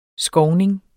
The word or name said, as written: skovning